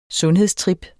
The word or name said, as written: sundhedstrip